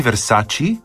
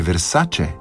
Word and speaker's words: Versace: Ni Versace, ampak je Versace.